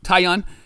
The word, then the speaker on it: Taillon: The the Yankees pitcher Talion, is that how you pronounce Taillon. it? – Taillon.